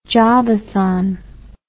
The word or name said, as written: jobathon